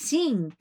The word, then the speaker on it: sim: Si is yes in both Spanish and Italian, but in Portuguese it's sing, and it's spelled S-I-M, but the M is pronounced like N-G. So you're almost saying sing, like sing a song.